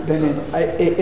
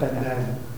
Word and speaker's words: Benin: If you're speaking with a French speaking person, they'll tell you it's Benin. – Also, is that accurate? – They don't pronounce the last name. – Yeah. – It's not Benin. – Benin. – Benin. It's a nasal.